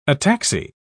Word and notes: ataxy: British English

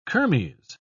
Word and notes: kermes: British English

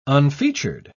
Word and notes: unfeatured: British English

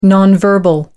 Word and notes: non-verbal: American English

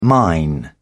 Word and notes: mine: British English

